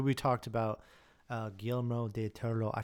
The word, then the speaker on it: Toro: Guillermo del Toro. Yeah. – Anyways, she is a big, huge horror fan. And remember to roll the R. Toro.